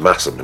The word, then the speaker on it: Masham: What is the sire of a Masham sheep? That's spelled M-A-S-H-A-M and pronounced Massam in actual fact.